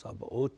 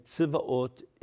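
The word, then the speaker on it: Tzivaot (צבאות): It's Tzivaot, and we say Sabaot in Hebrew, but in Hebrew, in English we use Sabaot, and Tzivaot is the Hebrew pronunciation.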